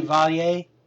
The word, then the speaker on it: Chevalier: Chevalier? – Yes.